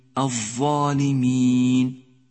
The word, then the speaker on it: الظالمين: لاحظ حرف الظاء في كلمة الظالمين، الظالمين، ولاحظ حرف الذال في كلمة عذاب، عذاب. حرف الظاء وحرف الذال يشترك معهما حرف الثاء، عند نطقهما يجب أن يلامس رأس اللسان طرفي الثنيتين العليين وأن يبرز قليلا حتى يمكن أن يراه الناظر، هكذا إذ إث.